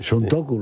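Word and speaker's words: Santoku: Luis Alberto, ¿ha pronunciado bien Suntoku en japonés o cómo es? – Los japoneses en la fonética es muy parecida. – Sí, Suntoku, lo ha dicho bien entonces.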